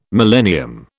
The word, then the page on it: millennium: British English